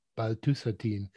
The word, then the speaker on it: paltusotine: So let's shift a little bit and talk about specifically about paltusotine, the first once daily oral non-peptide drug, right? Did I say that right? – Correct. Yeah, you're on it.